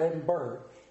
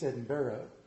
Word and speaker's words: Edinburgh: Back in the middle 1800s, 1820s, he got a position at Edinburgh University, and you say it like that. It's not Edinburgh, it's Edinburgh.